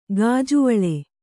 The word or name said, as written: ಗಾಜುವಳೆ